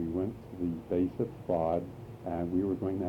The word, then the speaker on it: Safed: You know where Tzfat is. – Tzfat. – Tzfat, S-A-F-E-D. – Right. – S-A-F-E-D.